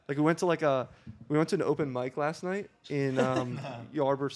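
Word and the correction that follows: Ybor: City or whatever it's called. – Ebor.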